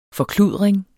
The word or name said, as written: forkludring